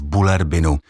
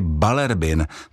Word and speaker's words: Bullerbyn: Pro jazykové okénko o češtině jsou Děti z Bullerbynu knížka zajímavá, protože, jak například pozoruji kolem sebe, není mezi Čechy úplně ustálená výslovnost jména vesnice Bullerbyn. Někdy slyším taky Balerbin, což je výslovnost, která se zřejmě snaží respektovat, že ve skandinávských jazycích, stejně jako třeba v angličtině, se slova často vyslovují jinak, než se píšou. Ale v případě Bullerbynu je výslovnost opravdu taková, jak vypadá psaná podoba toho jména.